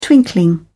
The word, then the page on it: twinkling: British English